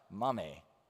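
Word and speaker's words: mame (μάμμη): The Greek word here that Paul uses for grandmother out of the ancient Greek is actually pronounced mame. Well, I just thought that was funny because for us, mame is like mommy. That's mom.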